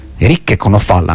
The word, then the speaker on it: Eric Conofal: I Blood Axis erano Annabelle Lee, Michael Mohinan, Robert Farbrake, di origine americana, mentre Le Joyeux de la Princesse, Eric Conofal. Eric Conofal, anzi, dato che è francese, quindi gli cambiamo l'accento.